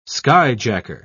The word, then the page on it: skyjacker: British English